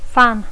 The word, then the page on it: fan: Catalan speaker; intermed